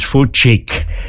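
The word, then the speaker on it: Fučík: His name was Julius Ernst Wilhelm, and the pronunciation is Fucik. F-U-C with a little V above it, which I believe is called a Karen, and that makes the little C a C-H sound, followed by I-K, F-U-C, I.K.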